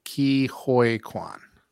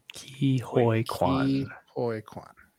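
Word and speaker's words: Ke Huy Quan: So, according to NPR, it's Ki Hoi Kwan. – Ki Hoi Kwan.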